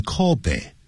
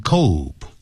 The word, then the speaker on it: Kobe: 都可以这样讲。It started out in Kobe，不要念成 Kobe，